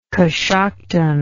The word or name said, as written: coshocton